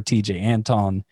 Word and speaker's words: Tejay Antone: TJ Anton. And that's spelled T-E-J-A-Y. That's TJ and Anton, A-N-T-O-N-E.